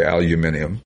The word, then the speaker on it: aluminium: or as the British say, aluminium, and the 12-bolt rear, and it's just a real, really well-done tribute.